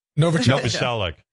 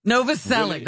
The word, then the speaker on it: Novoselic: Kurt Novelichik or whatever. I don't know how to say his name. The bass player from Nirvana. Novoselic. – Novoselic. That's it.